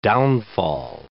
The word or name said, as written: downfall